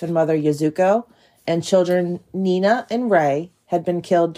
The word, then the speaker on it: Niina: So Nina is spelled N-I-I-N-A. So like Nina. – Okay. – And then Rei is spelled R-E-I.